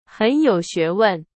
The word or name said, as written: 学问